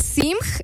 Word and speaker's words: Simg: Ana Simg. Ana S-I-M-G.